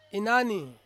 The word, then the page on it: inani: Ansus